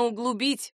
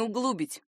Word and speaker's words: углубить: Именно углубить, а не углубить.